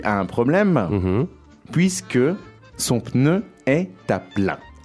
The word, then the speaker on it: pneu: It's got a P and an N. – Ouais. Donc la prononciation c'est pneu. – Pneu. Okay, so you do have to pronounce them.